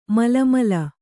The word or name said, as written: ಮಳಮಳ